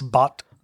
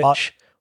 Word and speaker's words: Bodt: Bot. – B-O-D-T, which Bot.